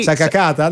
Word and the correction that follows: Sacagawea: Allora, è Sacagawea.